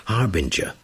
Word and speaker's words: harbinger: Harbinger. H-A-R-B-I-N-G-E-R. So it's a J, not a G, not a hard G, a soft G. So harbinger.